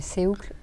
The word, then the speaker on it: CÈUCLE: Est-ce que tu peux nous, nous, donner donc nom de ta marque Ouais, alors la marque s'appelle Seoucle je sais que c'est pas évident quand on lit le nom. Mais, euh, mais ça se prononce comme ça du coup.